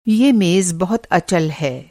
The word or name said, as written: अचल